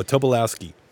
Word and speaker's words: Tobolowsky: Oh, Tobolowsky.